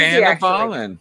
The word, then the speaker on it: Dhanabalan: It's pretty easy, Dhanabalan.